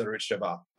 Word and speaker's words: Arutz Sheva: Is Israel national news the same thing as Arutz Sheva? – It is good pronunciation. It is the same as Arutz Sheva.